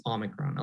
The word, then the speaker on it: Omicron: Usually we see in a lot of Greek words that come to English, we see a lot of this stress of the word at the opening of the word, the first syllable of the word. So that's why it's Omicron. A lot of people have been pronouncing Omicron or Omicron.